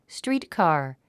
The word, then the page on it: streetcar: stríːtkὰɚ; ストリートカー